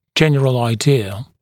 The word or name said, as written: general idea